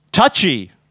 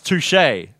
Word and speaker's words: touché: Touchy, I replied, and winked. – It's pronounced touché, said Holmes, evenly.